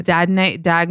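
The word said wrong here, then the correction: Dagne Dover: Oh my God, I'm saying it wrong. – Dagnate Dover? – Yeah.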